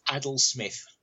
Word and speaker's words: Adil-Smith: It's pronounced Adil-Smith.